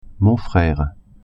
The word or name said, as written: frere